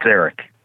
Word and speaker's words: Eric: It's Eric.